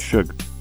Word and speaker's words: Suge Knight: Is it Suge Knight? – Shug. – Shug.